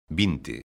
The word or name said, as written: vinte